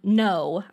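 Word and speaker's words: Noe: And no, I think is how you would pronounce it. It's N-O-E.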